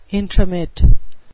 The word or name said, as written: intromit